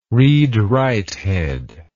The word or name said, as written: read write head